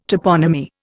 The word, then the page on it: toponymy: Indian